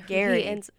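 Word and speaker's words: Gerry: And I was like, I just need to know who Jerry, the Golden Bachelor. – Gary. – Oh, wait. Is it Gary? – You said Jerry. – Is it Gary, though? – It's Gary. – Okay. It's spelled G-E-R-R. G-E-R-R-Y.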